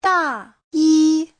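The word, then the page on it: dayi: da4yi1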